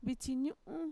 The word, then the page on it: vitxiñu'u: Mixtec